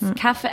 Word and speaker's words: kaffe: Because it's kaffe. How do you pronounce coffee in Swedish, by the way? – Kaffe. K-A-F-F-E.